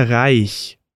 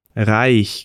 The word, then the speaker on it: Reich: Vamos agora pegar como exemplo a palavra Reich. Reich, que significa rico. Primeiro eu vou ter o som do R que sai lá da garganta e depois o som do CH.